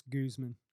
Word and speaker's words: Guzman: Real quick, just to make a statement, I believe it's pronounced Guzman. – Guzman? – Guzman. Yes.